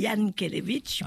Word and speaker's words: Jankélévitch: écorcher son nom, Jankélévitch. – Alors, effectivement, certains disent Jankélévitch, moi je dis Jankélévitch, puisque voilà. – Oui, le J se dit, se prononce Y, effectivement.